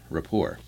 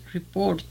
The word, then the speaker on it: rapport: It sounds like a French word. – Rapport. – Rapport. Because there's no T. You don't pronounce the T at the end. – Report. – Right? That if it was an English word, it'd be report. You know, it's... So we think it may be French.